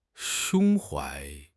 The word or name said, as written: 胸怀